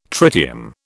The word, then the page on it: tritium: British English